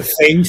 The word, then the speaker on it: Zenj: En la segunda parte del libro se entra también muy en detalle en la tierra de los Zeng, no sé si lo pronuncio, sí, bien, Zeng, Z-E-N-J, Zeng,